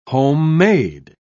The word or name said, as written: homemade